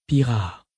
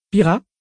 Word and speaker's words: Pira: Pira ? – Non, avec un accent bien plus prononcé sur le A. – Pira ? – C'est un peu mieux comme ça.